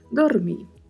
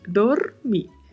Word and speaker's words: dormir: Chamo a atenção mais uma vez, assim como comentei no episódio anterior, para a pronúncia da letra D, que é sempre pronunciada com um pouco mais de suavidade, não muito marcado. Dormir. E o R, vibrato, sempre. Dormir. Seguimos na frase com mais um advérbio, outro advérbio, que é mucho, que está seguido de mais um advérbio, que é mas.